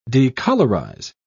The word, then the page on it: decolourize: British English